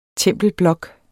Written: tempelblok

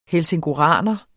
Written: helsingoraner

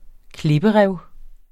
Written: klipperev

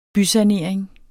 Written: bysanering

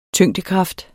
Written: tyngdekraft

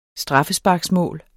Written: straffesparksmål

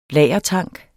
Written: lagertank

